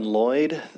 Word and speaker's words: Lloyd: My name is Alan Lloyd. That is spelled L-L-O-Y-D.